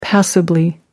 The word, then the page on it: passably: American English